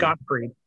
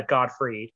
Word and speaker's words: Gottfried: Did I say his name right? I always fuck it up, and I'm sorry. – Bob Saget or Gilbert Gottfried? – Gilbert Gottfried. – Gottfried. G-O-T-T. Yeah, Gottfried. Gottfried.